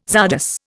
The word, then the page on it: czardas: Indian